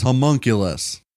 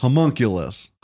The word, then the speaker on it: homunculus: Today's word of the day is homunculus. – Homunculus? – Homunculus. – Homunculus. – How do you spell it? – H. Yeah. Homunculus. H. U. M. M. U-N-K-U-L-U-S. Homunculus. – Homunculus. Yeah, you're right.